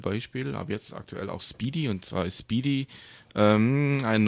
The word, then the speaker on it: SPDY: Also S-P-D-Y. – Ja, ich glaube, spricht man Speedy aus.